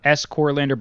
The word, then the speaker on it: Scorlander: So like S Corlander, but spelled like score, like a sports score, Scorlander.